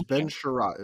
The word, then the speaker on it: Ben Chiarot: Apparently it's Ben Chiarot is how you say it.